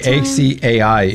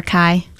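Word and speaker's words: açaí: By the way, ACAI is one of the mispronounced words ever. – I believe that. – Now, how do you say it? – Acai. – Acai. A lot of people sing acai. – Or akai.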